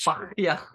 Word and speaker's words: Bahia: They sold him to Bahia, which probably definitely not how you pronounce it, because they're in Brazil, and I don't think Brazilians do a on their H. – Bahia.